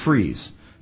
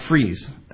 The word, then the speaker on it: frieze: And by the way, if you make a paper mache impression of a steel, that's called a frieze. Just, that's a frieze. And it's spelled funny as well. It's F-R-I-E-Z-E.